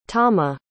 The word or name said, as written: tama